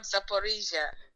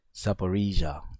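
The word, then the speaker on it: Zaporizhzhia: It's called Zaporizhia. – Zaporizhia.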